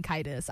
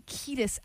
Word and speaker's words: Kiedis: I think I was saying Kiedis. I'm pretty sure. So I'm pretty sure I was saying that wrong. So that's Anthony Kiedis, actually.